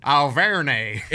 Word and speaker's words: Auvergne: Auvergne. – That's exactly how you would say it. And it's A-U-V-E-R-G-N-E.